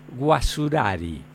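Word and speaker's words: Guacurarí: En el texto de ley se escribió el nombre como Andrés Guacurari y Artigas, porque así firmaba este personaje, aunque se pronunciaba Guasurari.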